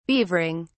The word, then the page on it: beavering: British English